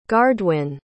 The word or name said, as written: gardwin